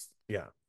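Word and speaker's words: Sokkerstr: Soccer.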